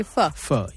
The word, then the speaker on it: pho: Pho. Yeah. – It's spelled P-H-O. – P-H-O.